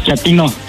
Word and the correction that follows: chatino: No, chatino. – Es como chato, ¿verdad? Chatino. – Sí, asunero como chato, chatino.